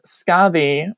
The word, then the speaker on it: Skaði: And the other one that I work with is Scathi, which is spelled S-K-A-D-H-I or D-I, depending on how you're transliterating the medieval letter that isn't in our language anymore. It's like a T-H sound, like a the Scathi.